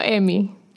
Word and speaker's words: Emmy: Ella ha sido nominada, escuchen bien, 13 veces al premio Emmy. ¿Lo dije bien? – Correcto.